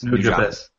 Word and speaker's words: Nujabes: It's from a song called Lovesick Part 4 by New Jobs or New Jabes or however you pronounce it. N-U-J-A-B-E-S. – New Jobs. There you go.